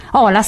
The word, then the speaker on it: Ola: Ola, sì, certo, ho detto bene,